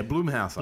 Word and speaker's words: Blumhouse: Yeah, Blumhouse.